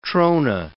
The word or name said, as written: trona